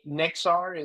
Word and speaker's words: Nexar: Eran Shir is the CEO founder of Nexar, which makes every, and by the way, let me, Nexar, am I pronouncing that correct? – Yeah.